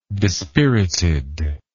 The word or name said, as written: dispirited